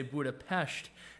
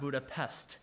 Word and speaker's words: Budapest: You're supposed to say Budapest, not Budapest. So when you see an S in the Hungarian language, you say it like an SH.